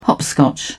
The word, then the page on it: hopscotch: British English